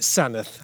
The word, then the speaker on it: Sandwith: It's spelt Sandwith. – Sandwith, but as locals pronounce it Sanneth.